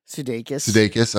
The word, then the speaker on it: Sudeikis: Sudeikis.